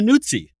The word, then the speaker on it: Nuzzi: And this week, I'm thrilled to have one of the more interesting, funny, sharp, and brilliant journalists of her generation, Olivia Nuzzi, that's how you pronounce it, right? – Yeah.